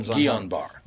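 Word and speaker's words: Guillain-Barré: Guillain Barre. – Yeah, it's in French. It'd be Guillain Barre.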